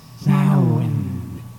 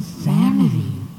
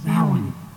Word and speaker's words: Samhain: That's Samhain. It's spelled Samhain, but if you say Samhain, it gets upset. It's pronounced Samhain.